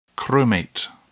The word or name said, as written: chromate